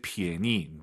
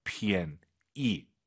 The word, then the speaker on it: 便宜: P-I-A-N-Y-I인데요. 절대 便宜. 니은 발음 들리면 안 돼요. 便宜. 便宜, 요거 기억하세요.